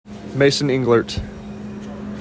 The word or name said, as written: Mason Englert